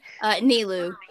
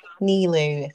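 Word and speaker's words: Nilou: You'll be voicing, is it Nulu, Nalu? – Nilu. – Nilu.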